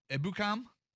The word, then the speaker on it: Ebukam: Ebukam? – Yeah, that's it.